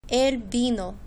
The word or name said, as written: el vino